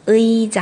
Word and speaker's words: 의: 好，那这里要注意一下，如果这个 e 放在第一个位置，单词当中就要念成啊，就念成它本来的。比如说像医生。 의 사 医 사 还有一个椅子，也是在第一个位置的这个 의 의 자， 의 자 好，要注意的另一点是，如果这个 의 不是在第一个位置，它是在后边了，这个时候我们要把它念成 e e，这个的话可以看成。